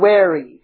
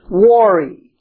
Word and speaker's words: wary: How many of you are familiar with the word wary? Or you may pronounce it worry.